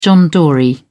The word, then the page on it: john dory: British English